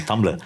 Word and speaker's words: Tumblr: How to pronounce Tumblr? – Tumblr.